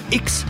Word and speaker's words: I'X: Ya regresamos a Radio Ciudadana 1350 de AM para seguir presentando grupos emergentes de todo México, como este proyecto llamado X, que se escribe I'X, cuyo género es el heavy metal, al mismo tiempo que maneja los subgéneros como el progresivo, thrash, doom y hard rock.